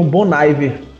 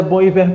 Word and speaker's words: Bon Iver: Só, só queria fazer um, um, disclaimer aqui, porque eu, eu queria fazer uma, uma carta aberta às pessoas que pronunciam Bonaiver. – Pelo amor de Deus, eu acho que se você botar o nome da banda na Wikipedia, eu acho que tem a pronúncia nas primeiras linhas e dá uma agonia muito grande, porque, tipo, o nome da banda é Bom e Ver por um motivo,